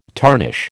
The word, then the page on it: tarnish: American English